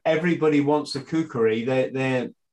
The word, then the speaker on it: kukri: So can we talk about the do I say it right? Kukri? – Kukri, yeah, Kukri. – Kukri, the legendary. – In Nepal, we said it's Kukri.